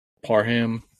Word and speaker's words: Parham: Joshua Palmer will come up, and then they use their tight end Donald Parham. Is that how you say it? – Yeah.